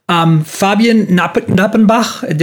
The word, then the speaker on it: Fabian Nappenbach: Fabian Nappenbach, did I say it right? – Correctly.